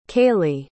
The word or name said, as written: kailey